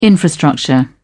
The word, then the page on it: infrastructure: British English